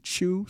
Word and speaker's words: Chu: And his, I think I'm pronouncing his name right, Chu, C-H-U? – Mm-hmm.